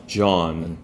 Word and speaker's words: John: 존 아니고 뭐죠? – John. – John 이렇게 하시고 I am John 안 돼요.